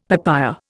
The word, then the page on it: papaya: Indian